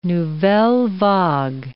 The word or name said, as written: nouvelle vague